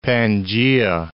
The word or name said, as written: Pangaea